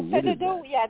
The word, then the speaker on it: Fedodo: Which is completed, and you have two movies in post-production, The Uncanny, and I don't know how to pronounce this, Fios Dodo, is it? Or Dodo? – Oh, Fedodo, Fedodo is a short that I did.